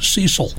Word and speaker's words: Cecil: His family pronounced his name Cecil in the American fashion.